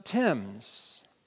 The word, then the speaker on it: Thames: Later, Reverend Kent Douglas pulled me aside and said, that river is pronounced Thames.